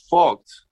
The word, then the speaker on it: Voigt: And I went out there and Chris Voigt, spelled V-O-I-G-T, so it's spelled Voigt, but Chris Voigt, was my biggest competitor in distance.